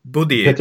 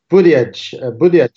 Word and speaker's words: Buttigieg: Och Buttigieg, Peter, tror jag man säger. – Buttigieg.